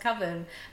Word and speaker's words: coven: And somebody mentions to him at one point, oh, it's pronounced coven. And he goes, no. Coven, it rhymes with oven.